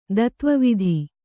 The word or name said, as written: ತತ್ವವೇತ್ತ